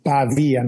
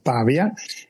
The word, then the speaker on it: Pavia: The stress is on Pavia, not Pavia.